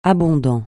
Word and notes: abondant: francais

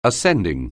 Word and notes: ascending: anglais

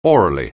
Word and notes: aurally: anglais

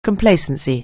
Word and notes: complacency: anglais

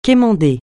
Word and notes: quémander: francais

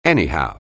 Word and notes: anyhow: anglais

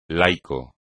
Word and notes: laico: Spanish

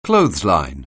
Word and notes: clothesline: anglais

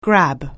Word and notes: grab: anglais

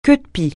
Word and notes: queue-de-pie: francais

